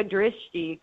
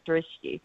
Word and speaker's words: Drishti: Drishti? – I am. Hello. Thank you for having me. It's Yoga Drishti, community wellness, and we often just call it Drishti. – Drishti. Okay. Yoga Drishti, yep, community Drishti.